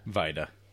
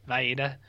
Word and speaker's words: Wajda: So, and like this uh this dude, this Wajda guy, or how do you say Vida? – Vida. – Vida.